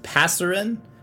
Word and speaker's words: Passerine: It's Passerine, P-A-S-S-E-R-I-N-E, Passerine. I would describe myself as relatively sure that I am pronouncing that properly.